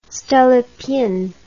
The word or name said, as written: stolypin